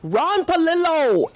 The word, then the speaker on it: Ron Palillo: Yes, I would, because we have Ron Palillo.